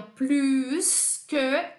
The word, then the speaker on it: plus que: É o plus que e se pronuncia o S. Sempre vai ser plus que, Jana, plus que alguma coisa. Então, nessa comparação, sim, pronunciamos o S.